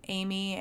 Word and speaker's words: Amy: I do like my name best spelt Amy, A-M-Y, C for my middle initial, and Nichols, N-I-C-H-O-L-L-S.